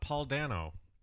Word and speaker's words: Paul Dano: How about Paul Dano? It's like Volcano, if you say it right.